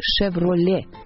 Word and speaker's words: Chevrolet: Поэтому фамилию и, соответственно, название компании следует произносить «Chevrolet» без «т» на конце.